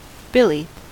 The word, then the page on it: billy: American English